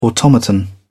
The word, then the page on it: automaton: British English